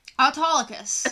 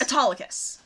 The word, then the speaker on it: Autolycus: Autolycus. – Autolycus.